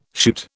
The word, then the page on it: shoot: British English